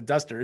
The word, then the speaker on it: Duzter: I love that you corrected me, because I want to be saying that wrong. So Duzter is perfect.